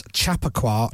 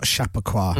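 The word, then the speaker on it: Chappaqua Wrestling: And then we realised it's Chappico, not Chappico.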